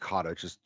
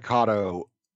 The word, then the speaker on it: Kotto: Yaphet, I think it's pronounced Kotto, just for clarification. – Sure. – Yaphet Kotto.